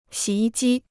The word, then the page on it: 洗衣机: xǐ yī jī